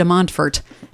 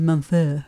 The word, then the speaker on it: Montfort: Yeah, we were talking before we started recording about how you actually pronounce his name, and I said, well, you know, we Irish Germans in Dubuque say St. Louis de Montfort, and I'm sure in French it's probably something like St. Louis de Montfort.